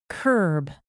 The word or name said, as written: kerb